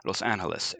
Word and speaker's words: Los Angeles: So they used the Spanish pronunciation of the city, Los Angeles.